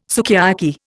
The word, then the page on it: sukiyaki: Indian